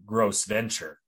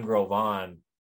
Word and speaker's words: Gros Ventre: And his teacher at the time was like an ignorant white lady, and she kept calling the Grove on gross venture, because that's how it's spelled, right? – Oh. – And he like was like, hey, like, I'm Grove on, that's how you say it, like, it's because of the French, you know, coming over and like naming us that.